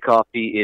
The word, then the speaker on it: Coffey: Just search my name, Nick Coffey. It is pronounced like the drink, but it's spelled C-O-F-F-E-Y.